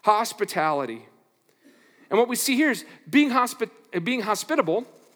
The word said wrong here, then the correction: hospitable: put the wrong emphasis on the wrong syllable in a second there. Being hospitable is a lot about who you are more than what you do.